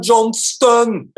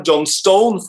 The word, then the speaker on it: Johnston: og det er greit, men det uttales ikke Sam Johnstone for de som tror det, selv om det er Stone. Det er Johnston, og det høres veldig ut som Johnson.